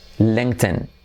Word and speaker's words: LinkedIn: ¿Cómo se pronuncia LinkedIn en inglés? Fíjate, LinkedIn. LinkedIn. Es casi como otro idioma distinto. LinkedIn. ¿Por qué? Porque tenemos la letra clave L, Manuel Miguel Raquel, y dos letras I como E, la primera length y luego la última N son es, ¿vale?